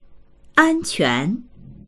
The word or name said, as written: an1quan2